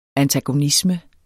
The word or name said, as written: antagonisme